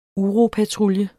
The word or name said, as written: UROPATRULJEN